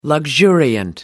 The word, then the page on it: luxuriant: American English